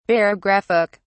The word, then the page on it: barographic: British English